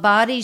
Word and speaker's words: bawdy: Called Body Storytelling. And body, just to be clear. – Which is spelled B-A-W-D-Y.